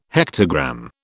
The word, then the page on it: hectogram: British English